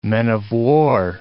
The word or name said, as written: men-of-war